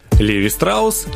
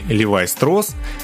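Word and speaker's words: Levi Strauss: Леви Страус, или в оригинальном произношении Левай Строс, родился в Германии, в Баварии 26 февраля 1829 года.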